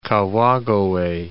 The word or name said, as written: Kawagoe